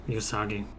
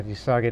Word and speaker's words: Uesugi: It's spelled U-E-S-U-G-I. Do you know how to pronounce that in Japanese? – Yusagi. – Yeah, Yusagi.